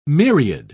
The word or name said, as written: myriad